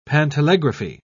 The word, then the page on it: pantelegraphy: British English